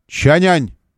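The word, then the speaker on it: Changan: В соответствии с правилами китайско-русской транскрипции, по-русски это название читается... Вот это да, оно читается «Чанянь».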